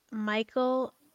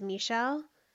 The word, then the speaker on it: Michel: And I've heard her name be Michael, but I think the actual pronunciation is Michelle.